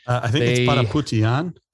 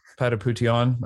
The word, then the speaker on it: Patapoutian: It's Patapoutian. – Patapoutian. Okay, I'm just going to call him Ardem. – So actually, you know, he's from my hometown. – Oh, no. Really? – Yeah. Ardem Patapoutian, born in Beirut, Lebanon.